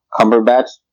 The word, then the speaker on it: Cumberbatch: And when it comes to Benedict Cumberbatch, that's how you pronounce his last name? – Yep.